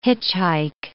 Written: hitchhike

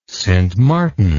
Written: Sint Maarten

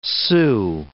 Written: Sioux

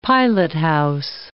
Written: pilothouse

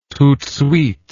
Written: tout de suite

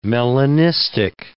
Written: melanistic

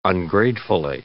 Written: ungratefully

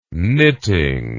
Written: knitting

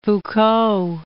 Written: Foucault

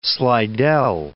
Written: Slidell

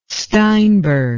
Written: steinberg

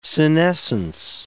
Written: senescence

